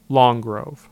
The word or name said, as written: Long Grove